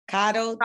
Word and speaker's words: coddled: A lot of y'all have gotten not coodled. How do you say that? Coddled, like cuddling a child.